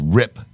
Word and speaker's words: Rippe: I found out how you pronounce the last name. It's Rip. R-I-P-P-E, Rip.